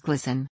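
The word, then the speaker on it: GLSEN: During the special, Nina West, real name, Andrew Levitt, encouraged viewers to support Gilson, pronounced Glisson.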